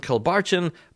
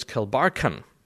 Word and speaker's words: Kilbarchan: I was calling them Kilbarchan, but it's Kilbarchan.